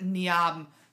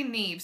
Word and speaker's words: Niamh: They pronounce it Niamh. It's actually Niamh.